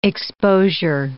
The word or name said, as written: exposure